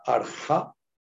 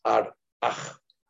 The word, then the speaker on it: Ar-Aj: y al expirar permite el sonido ar Hasta ahora siempre les decía arja. – Sin embargo, en el último taller de Nil nos dice que la pronunciación correcta es Ar-Aj.